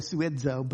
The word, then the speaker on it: sozo: The English spelling of it is S-O-Z-O, and so people pronounce it sozo. That's fine. It's, you know, ancient Greek, it's probably suedzo, but we'll just call it sozo because that's what it looks like and that's what most people relate to.